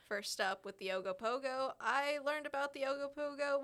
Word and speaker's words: Ogopogo: The Ogopogo, you know, like a pogo stick.